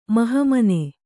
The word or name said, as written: ಮಹಮನೆ